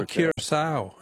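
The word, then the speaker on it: Curaçao: And I don't know if I'm pronouncing it right, but I thought it was Curacao. – Oh, Curacao, yeah. No, that's very good. I'm sure you're pronouncing it correctly, but that's not...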